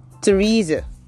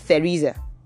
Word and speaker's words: Teresa: Then you have Teresa. So it's not Theresa. It's Teresa or Teresa. It could be Teresa or Teresa.